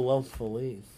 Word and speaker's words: Los Feliz: Oh, Los Feliz.